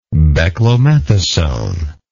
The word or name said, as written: beclomethasone